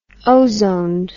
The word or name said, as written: ozoned